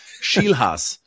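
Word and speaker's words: Scheelhaase: Nate Schielhaas, glaube ich, wird da ausgesprochen. – Ja, glaube ich auch. Ja. – Nate Schielhaas, das ist der Schielhase, ja. Das macht es einfacher. Schielhaas.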